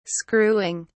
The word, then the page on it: screwing: British English